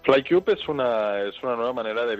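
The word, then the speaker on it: FlyKube: ¿FlyCube? – Sí, exactamente. FlyCube. Fly de volar en inglés y cube de cubo en inglés, pero con K.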